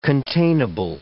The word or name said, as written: containable